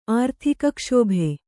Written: ಆರ್ಥಿಕಕ್ಷೋಭೆ

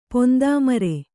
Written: ಪೊಂದಾಮರೆ

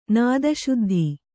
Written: ನಾದಶುದ್ಧಿ

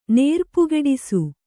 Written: ನೇರ್ಪುಗೆಡಿಸು

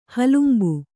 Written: ಹಲುಂಬು

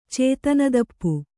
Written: ಚೇತನದಪ್ಪು

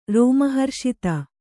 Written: ರೋಮಹರ್ಷಿತ

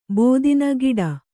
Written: ಬೋದಿನ ಗಿಡ

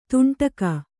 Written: ತುಂಡುಗೈ

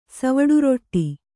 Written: ಸವಡುರೊಟ್ಟಿ

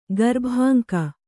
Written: ಗರ್ಭಾಂಕ

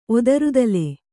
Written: ಒದರುತಲೆ

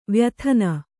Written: ವ್ಯಥನ

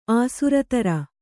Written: ಆಸುರತರ

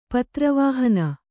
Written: ಪತ್ರವಾಹನ

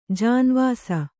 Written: ಜೀನವೆಸ

